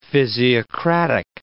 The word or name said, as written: physiocratic